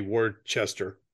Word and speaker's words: Worcester: And when I say the Worcester Races, that's, I think, how it's pronounced. In America, we would probably say Worcester.